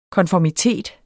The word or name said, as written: konformitet